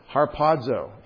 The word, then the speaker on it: harpazo: That is harpazo. H-A-R-P-A-Z-O is the lexical form.